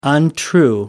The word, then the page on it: untrue: American English